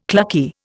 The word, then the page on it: clucky: Indian